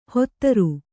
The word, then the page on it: ಹೊತ್ತರು: hottaru